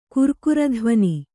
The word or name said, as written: ಕುರ್ಕುರಧ್ವನಿ